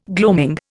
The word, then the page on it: gloaming: Indian